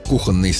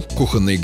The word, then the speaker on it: кухонный: Но в живой речи вариант «кухонный» встречается довольно часто. Он привычен на слух и поэтому легко закрепляется, хотя нормой не считается. Иногда его даже не замечают, пока не нужно произнести слово правильно. Чтобы не путаться, помогает простая логика. Кухня. Кухонный.